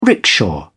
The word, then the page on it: rickshaw: British English